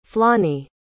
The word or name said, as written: flonnie